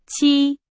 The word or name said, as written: 期